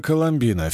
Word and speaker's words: Коломбина: На столь необычайный вызов со мной отправилась белокурая Коломбина, фельдшер медсанчасти. Вообще-то это фамилия, с ударением на последнем слоге.